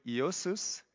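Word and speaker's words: Iosus (Ἰησοῦς): Iosus is how you say that in Greek. It's close.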